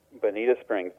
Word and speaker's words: Bonita Springs: Bonita Springs.